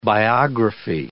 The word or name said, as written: biography